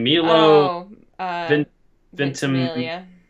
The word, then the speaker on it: Milo Ventimiglia: Milo Ventimiglia.